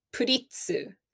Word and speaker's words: Pretz: They're called puritsu, but it's spelled P-R-E-T-Z. And I cannot look at it without just thinking pretz because it looks like the word pretzel. But for some reason, it's not pronounced that way.